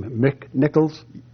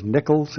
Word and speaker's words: Nicolls: I believe you're pronouncing the name Mick Nichols. – Yes. – The name is Nichols, and it's spelled N-I-C-O-L-L-S.